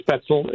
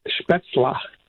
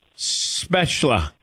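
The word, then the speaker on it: Spätzle: It's not Spetzel. It's Spetzla. – Spetzla.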